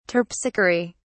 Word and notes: terpsichore: British English

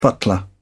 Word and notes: butler: British English

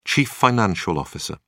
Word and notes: chief financial officer: British English

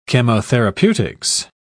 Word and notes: chemotherapeutics: British English

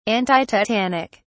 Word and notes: antitetanic: British English

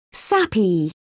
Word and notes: sappy: British English

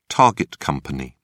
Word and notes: target company: British English

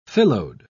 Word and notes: phyllode: British English

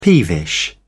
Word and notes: peevish: British English

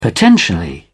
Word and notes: potentially: British English

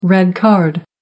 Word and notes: red card: American English

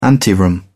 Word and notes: anteroom: British English

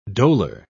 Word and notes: dolor: British English

